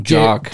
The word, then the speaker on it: JOK: Yeah, J-O-K, we call them Jock.